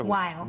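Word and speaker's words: Weil: Well, I was thinking, somebody asked you what your favorite, I'm saying her name wrong, Wheel? – Or Wile.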